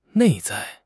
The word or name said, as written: 内在